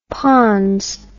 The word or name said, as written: ponds